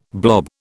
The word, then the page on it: blob: British English